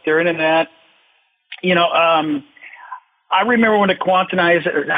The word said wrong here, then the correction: quantized: I always say that word wrong, you know, like quantumization or I always say it wrong. – It's quantized, snapping to the grid, whatever, yeah.